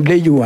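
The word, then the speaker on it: leviou: Le pluriel, c'est « Léviou », hein. Ici, on prononce « Léviou », hein.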